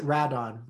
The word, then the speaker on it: Rodan: Well, they pronounce it Radon when you're watching it. – Oh, is that it? Okay. – Yeah. So they call it Radon, you find this out, because of the gases that it exudes around it.